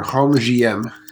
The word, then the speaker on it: Rhum J.M: Rhum JM. – Okay. – Rhum JM.